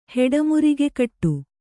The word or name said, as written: ಹೆಡಮುರಿಗೆಕಟ್ಟು